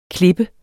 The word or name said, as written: klippe